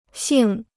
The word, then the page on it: 姓: xìng